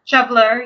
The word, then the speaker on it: shoveler: How do you pronounce that? Shoveler? Shoveler, yep. I've never heard that name, but you know, that mama duck does anything she can to protect her babies, right? – Yep.